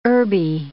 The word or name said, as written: herby